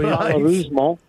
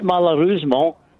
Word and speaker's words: malheureusement: Malheureusement. – Give us that again. – It's not malheureusement, it's malheureusement.